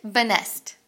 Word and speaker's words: Benest: Benest. – All right. That's all. Lisette Benest. It rhymes.